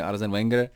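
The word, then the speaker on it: Arsène Wenger: kde teda Arzena Veněra, nevím proč jsem vyslovoval e, takhle, e, je to samozřejmě Arzen Wenger,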